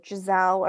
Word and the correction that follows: Ghislaine: or she's not Giselle, Gazelle, the one, Jeffrey Epstein's chick. – Oh, Ghislaine. – Ghislaine, yes. Okay,